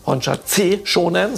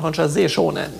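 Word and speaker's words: Hon Sha Ze Sho Nen: Ist das nicht Honsha C Shonen, sondern Honsha Se Shonen. Ja? So, also Z wird ausgesprochen in der Umschrift wie ein Z, ganz weich.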